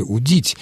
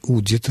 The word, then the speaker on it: удит: Он еще говорил об ударении в глаголе «удить». Да, здесь он прав. Телевизионный ведущий допустил ошибку. Конечно, царь удит рыбу, а не удит. Хотя в неопределенной форме «удить» ударение на суффиксе после корня, в личных формах этого глагола ударение на корне «удит».